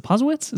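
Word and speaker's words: Posewitz: Is it Posowitz? Is that how you say it? – Yeah.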